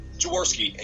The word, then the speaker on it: Jaworski: Jaworski, and you can just go with Tom for the first name.